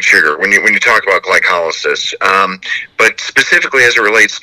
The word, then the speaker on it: glycolysis: It's in every one of the metabolic processes in the body, such as glycolysis. Did I pronounce that right? – Hey, you pronounced it perfectly.